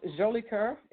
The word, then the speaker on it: Jolicoeur: Oh, it's, well, it's uh, pronounced Jolicoeur. It's uh, French Canadian, but the American Jolicoeur.